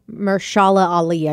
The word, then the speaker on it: Mahershala Ali: And Mahershala Ali, I believe, is how you pronounce his name. – Yeah.